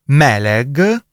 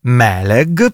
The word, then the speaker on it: meleg: L'aggettivo ungherese meleg, leggi come in italiano con entrambe le aperte, accento tonico sulla prima, è G di gatto, meleg, per cui l'ho pronunciato credo bene,